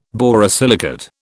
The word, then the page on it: borosilicate: British English